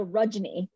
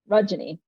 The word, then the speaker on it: Rajani: But I tell people when they're confused about how to pronounce my name, imagine it rhyme. The first part rhymes with fudge. So Rajani, Fudgeny. So fudge.